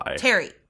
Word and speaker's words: Tarry: How do you spell that, brother? – T-A-R-R-Y. – Terry.